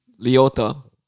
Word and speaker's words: Liotta: Loletta. Liotta. Ray Liotta. – All right.